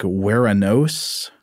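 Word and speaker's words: Ouranos: And then he sort of points us to the fact that, from what I can tell, it might be that the Greek Uranus, or O-U-R-A-N-U-S, might be pronounced something kind of like Weranos. – Hmm. – Weranos.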